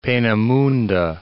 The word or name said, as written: Peenemunde